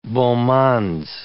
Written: beau mondes

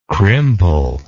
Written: crimple

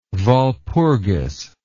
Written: Walpurgis

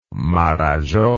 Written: Marajo

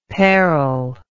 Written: pairle

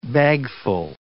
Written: bagful

